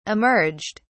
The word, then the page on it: emerged: British English